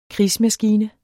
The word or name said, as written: krigsmaskine